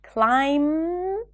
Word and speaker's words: climb: Do not pronounce the B in words ending with the spelling pattern MB, like climb, comb, lamb, bomb.